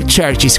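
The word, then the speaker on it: Chvrches: No, non sono i Beatles, non sono nemmeno gli Oasis e nemmeno i Blur, sono degli emeriti sconosciuti che si fanno chiamare Churches, ma attenzione, al posto della U c'è una V, quindi difficile da pronunciare, in realtà è un modo come l'altro per chiamarsi Churches.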